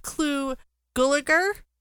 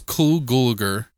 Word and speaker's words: Clu Gulager: We have Clu Gulager, who plays Lieutenant Ed Flynn. – Good call. You said that right. Okay. Everybody fucks his name up. – Oh, I don't know. – It's Cool Gulager.